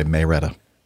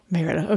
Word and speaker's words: Marietta: Marietta is the correct pronunciation, okay. But a lot of us say Marietta. – Marietta.